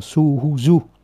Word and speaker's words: suhuju: Uju câu tiếp theo là Ujucha, tức là su cộng uju thì đọc là suhuju, su cộng uju thì đọc là suhuju, tức là khéo chánh trực, chỉ có tiếp động ngữ su ở đây là tốt đẹp, khéo léo, thiện lành.